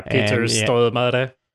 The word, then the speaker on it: Peter Stormare: Yeah, Peter Stormare.